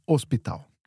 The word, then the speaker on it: hospital: So in Portuguese, the H at the beginning of a word is never pronounced. – Mm. – So hospital is spelled with an H, H-O-S. But we never pronounce that, so hospital.